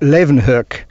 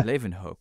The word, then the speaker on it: Leeuwenhoek: The way that you should say is something like Leeuwenhoek.